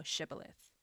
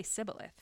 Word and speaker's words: Shibboleth: If he say, 'Nay,' then they say unto him, 'Say now Shibboleth.' And he say, 'Sibboleth,' for he could not frame or pronounce it right.